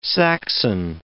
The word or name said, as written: Saxon